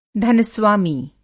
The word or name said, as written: धन-स्वामी